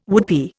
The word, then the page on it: woodpie: Indian